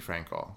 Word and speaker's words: Frankel: If you listen to Bethany Frankel, am I saying her last name right? – Bethany Frankel.